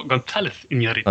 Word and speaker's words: González Iñárritu: González Iñárritu.